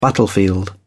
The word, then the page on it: battlefield: British English